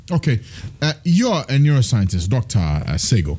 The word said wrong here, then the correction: Tego: You keep pronouncing it with an S, don't you? – Dr. Sego. – Tego. With a T. – Oh, Dr. Yeah. Tego. Oh, Tego.